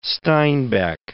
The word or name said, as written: Steinbeck